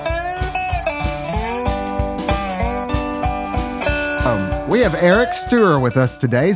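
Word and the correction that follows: Steuer: It's pronounced Stoyer, actually. – Stoyer. Okay, that's an interesting name. We have Eric Stoyer with us today,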